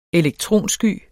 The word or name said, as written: elektronsky